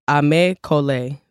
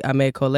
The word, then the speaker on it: Aimé Cole: So it's called Aimé Cole. So it's spelled AimeCole, Aimé Cole.